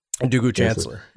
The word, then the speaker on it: Ndugu Chancellor: Ndugu Chancellor.